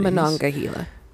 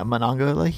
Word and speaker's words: Monongahela: Oh, did I not say that correctly? Because I know how to say that. I said it incorrectly. I apologize. – I just figured it was a typo by ChatGPT. – Yeah, Monongahela. It's just my North Carolina accent.